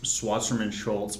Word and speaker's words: Wasserman Schultz: How do you pronounce Debbie Schwasserman Schultz? What's this lady's name? – Yeah, let's go with Wasserman Schultz.